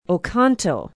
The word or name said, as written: oconto